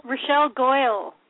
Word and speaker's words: Rochelle Goyle: And I just bought, from somebody on the arena, I just bought, I hope I'm pronouncing her name right, Rochelle? The Gargoyle Girl? – Rochelle Goyle. – Yes.